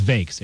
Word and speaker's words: Voeks: I don't really know how to pronounce this guy's name. V-O-E-K-S. Voeks. Vakes? I think it's Vakes. Eric Vakes.